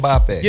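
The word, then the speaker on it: Mbappé: Kylian Mbappe.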